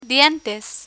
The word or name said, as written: dientes